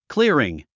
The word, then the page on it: clearing: クリアリン